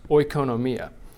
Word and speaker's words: oikonomia: oikonomia.